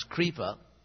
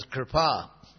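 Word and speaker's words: Kṛpā: Kripa, which we often pronounce Kripa, and then it gets really good when you, that famous lady from Mahabharata, Creepy. But anyway, the Sanskrit word is Kripa.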